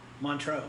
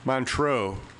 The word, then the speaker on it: Montreux: How do you say Montreux? – Montreux. Yeah. – Montreux.